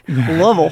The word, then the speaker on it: Louisville: Okay, Louisville. Thank you for saying it right. That was great. Louisville.